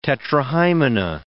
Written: tetrahymena

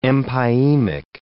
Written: empyemic

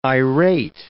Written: irate

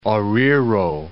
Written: Oruro

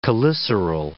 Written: cheliceral

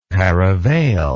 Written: paravail

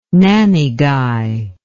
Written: nannygai

